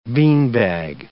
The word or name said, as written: beanbag